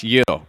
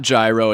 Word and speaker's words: gyro: It's pronounced Euro, right? It's not gyro, it's Euro. – No, you got it right, Euro.